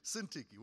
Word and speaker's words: Syntyche: If everybody's focusing on that one, he says, Yodia and Syntyche will not have now, you may get a pronunciation. That's how I pronounce it simply because I do read Greek, so that's my pronunciation. Syntyche, what a name, right?